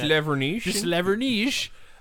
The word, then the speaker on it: De Slavernij: It was actually, the ad was created as some sort of viral marketing for a Dutch TV series called De Slavernij. I'm probably butchering that. – De Slavernij.